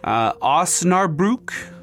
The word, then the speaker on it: Osnabrück: Osnabrück. Osnabrück. O-S-N.